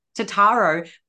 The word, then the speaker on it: Totaro: It's funny that you can't pronounce my last name because it's Totaro, but it used to be Vassilopolis, which is so much harder.